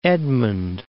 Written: Edmond

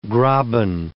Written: graben

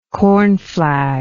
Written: cornflag

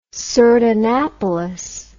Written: sardanapalus

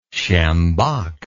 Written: sjambok

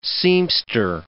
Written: seamster

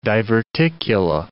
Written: diverticula

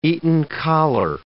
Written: Eton collar

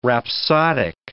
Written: rhapsodic

